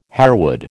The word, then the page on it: harewood: American English